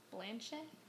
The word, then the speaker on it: Blanchett: Same with Kate Blanchett. Am I saying that correctly? – Kate Blanchett. – Blanchett? – Blanchett.